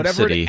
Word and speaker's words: Abcde: Yeah, Abstity. – Abstity. And it's spelled literally ABCDE.